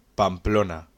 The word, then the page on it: Pamplona: Spanish